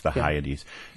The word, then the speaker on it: Hyades: Is it Hyades? – Hyades.